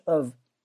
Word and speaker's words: of: 另外，请你注意这个 of 这个音这个单词，很多学生读成 of，它不是 of。你去查一下音标，它的音标是 of，是一个 a 加一个 v 的发音，说 of。